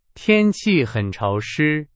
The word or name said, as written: 潮湿